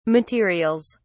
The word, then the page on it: materials: British English